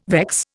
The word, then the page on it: vex: Indian